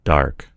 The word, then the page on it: dark: American English